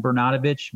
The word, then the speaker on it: Bernatovicz: It's John, J-O-H-N, Bernatovicz, B-E-R-N-A-T-O-V-I-C-Z.